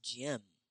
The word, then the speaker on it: gym: Is the it is the J sound, J, as in gym. We spell gym as G Y M, but we pronounce it as the J M. Georgia M, which is a J, a short I, and the M sound. Mm. J, J-M, J-M.